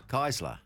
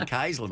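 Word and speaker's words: Kaesler: That's K-A-E-S-L-E-R. – Yes. – How do you pronounce it? – Keisler. – Ah, well, there you go. So, thank you. Thank you. You're not a Bergen. – What did you say? Keisler, mate. – Keisler.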